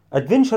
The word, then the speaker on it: adventure: of adventure، adventure adventure اللي مغامرة، adventure التي يو تنطق ش adventure، خلاص كده.